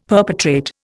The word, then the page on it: perpetrate: Indian